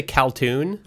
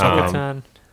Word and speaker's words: Kul'tan: Did you say Kaltoon? – I think I said it wrong. – It's Kul'tan. – Kul'tan. – Okay, I was gonna say, I wasn't sure if like the monks were Kul'tan but the town was Kul'tan. – Yeah. – And I was like, that's confusing, but I'll give you props for not. – Yeah. No, I just said it wrong. I've done that so many times in my head where I've said Kaltoon instead of Kul'tan.